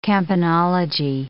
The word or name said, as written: campanology